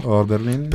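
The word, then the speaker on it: Hölderlin: Dunque, da Leopardi, appunto, non ripeto, ripetilo tu. – Holderlin. – Perfetto, così non faccio stupidaggini.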